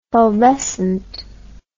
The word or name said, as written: fulvescent